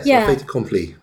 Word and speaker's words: fait accompli: So that basically it was a, how do you pronounce fait accompli? – Yes, fait accompli.